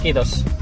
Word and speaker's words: Kiitos: How do you say thank you in Finnish? – Kiitos. – Kiitos? – Kiitos. – Like kudos, I suppose.